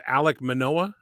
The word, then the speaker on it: Alek Manoah: Alek Manoah, I think is how mm-hmm. you say his name.